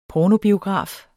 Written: pornobiograf

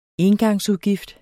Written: engangsudgift

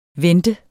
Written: vente

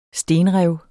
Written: stenrev

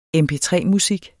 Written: MP3-musik

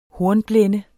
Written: hornblende

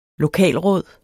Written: lokalråd

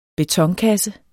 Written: betonkasse

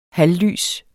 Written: halvlys

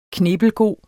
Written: knippelgod

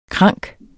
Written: krank

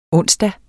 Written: onsdag

